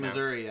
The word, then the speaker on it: Missouri: Is that the accepted saying? – In Missouri, yeah. Okay. That's how they say it.